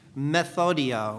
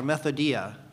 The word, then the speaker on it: methodia: The word wiles here is the word in Greek methodia or methodia. What does that sound like? Method. Method, right? It sounds like the word method, and that is the word from which we get the English word method.